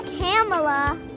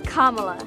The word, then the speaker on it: Kamala: It's not Kamala. It's not Kamala. It's not Kamala. It's Kamala.